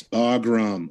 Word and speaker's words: Bagram: Oh, I know I mispronounced. It's Bagram.